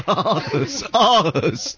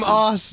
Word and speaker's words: Aarhus: Bertha Mud Child from Anus, Denmark. – It's Aarhus. From Aarhus, Denmark.